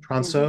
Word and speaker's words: Transeau: And is I think the way you pronounce his last name is Tronsu, Transeau, mm-hmm. Brian Transeau the guy's amazing.